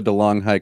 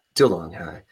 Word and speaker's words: DeLonghi: I'm drinking a delicious, Miel latte that I made myself from my Marva DeLong High coffee maker. – Yes. This episode is brought to you by DeLong High. I think they pronounce it DeLonghi, which is so ridiculous.